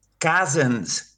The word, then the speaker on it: Cousins: todo el mundo, y, me pasa a los alumnos, es un nombre muy difícil de pronunciar porque es, bueno, Cousins, ¿no? Que es primo en inglés, Cousins, no Cousin.